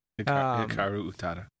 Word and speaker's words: Hikaru Utada: Is it Hikaru Utada? – Yeah, Utada. Hikaru Utada.